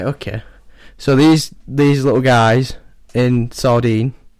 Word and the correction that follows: Sardinia: is it just called Sardine? – Sardinia. – Sardinia. – Oh. Sardinia.